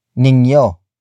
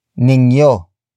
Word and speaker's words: ninyo: The next word is ninyo. Ningyo. Ningyo. Ningyo is spelled as N-I-N-Y-O.